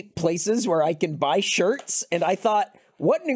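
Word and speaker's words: Schertz: I think I'm in a place called Shirts. And I didn't know that it was spelled like S-C-H, whatever. – E-R-T-Z.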